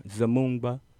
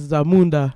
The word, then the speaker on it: Zamunda: It's a beautiful day in Zamunda. Cuz, that's how you say it, cuz? – Nah, cuz, it's Zamunda.